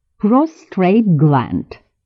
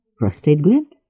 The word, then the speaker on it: prostate: И все же нужно сказать, что неправильное произношение некоторых слов – это зачастую признак низкого происхождения, указывающий на необразованность говорящего. Например, «nuclear» вместо «nuclear» – ядерный, или «prostrate gland» вместо «prostate gland» – предстательная железа.